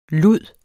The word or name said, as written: lud